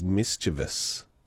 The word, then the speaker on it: mischievous: Yes, that one is, I believe the proper way to say that is mischievous. Is it? Mischievous. Often pronounced mischievous.